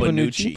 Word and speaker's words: Bonucci: Bonucci.